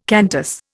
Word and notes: Qantas: Indian

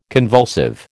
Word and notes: convulsive: American English

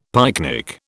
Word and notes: pyknic: British English

